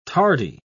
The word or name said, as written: tardy